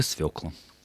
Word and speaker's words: свекла: Яна спрашивает, как правильно поставить ударение в слове «свекла»? – Только «свекла».